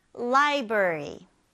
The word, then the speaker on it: library: 근데 여기에서 중요할 거는 이제 r이 중복돼서 들어가다 보니까 이게 r 발음이 조금 어려울 수가 있어요. 그래서 library를 한번 혀를 잘 굴려서 발음해 보세요. 자, 그러면 library.